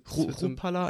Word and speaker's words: Chrupalla: Äh, Tino Schrupalla ist der Name, ich kann es nicht ganz genau aussprechen, also C-H-R-U-Palla. Schrupalla einfach ja, nur.